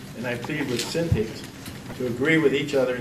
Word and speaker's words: Syntyche: Yeah, it's Eudia and Syntyche.